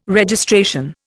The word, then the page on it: Registration: Indian